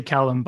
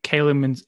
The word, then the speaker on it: Callum: It's probably not us, but I think it's a Scottish name originally, and so they probably here in England we always say Callum, but I think Calum is an American thing.